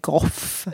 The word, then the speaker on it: Gough: det är väldigt svårt att eh, veta hur man skulle säga goff. Ja. O-O-G-H eh, kan man eh, uttala på åtta sätt på engelska, det är inte lätt.